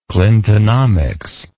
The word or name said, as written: clintonomics